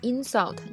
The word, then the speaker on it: insult: 比如说 insult，它的动词呢重音在这个 s u 上，啊，但它名词会在前边 insult。